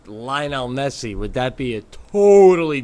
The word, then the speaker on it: Messi: in terms in terms of the soccer world, if he said all this about Lionel Messi. Is that that's how I pronounce it, right? Yeah. And he had literally was showing you photos with him and Lionel Messi and maybe even went so far as to got Lionel Messi on the phone, on speakerphone, for you to say hi to.